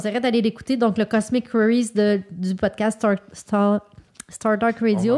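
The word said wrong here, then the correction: StarTalk Radio: On va mettre le lien parce que c'est mal prononcé là, sur le Facebook. – Star Talk Radio.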